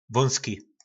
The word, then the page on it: Vóngli: Uhlí